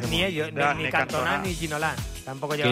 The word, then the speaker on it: Ginola: Mira, nos decía un oyente, Cantona o Ginola no tenían acento y se acentuaban en la última sílaba.